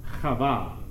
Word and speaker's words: Chava: So her name, the Hebrew word, is pronounced Chava.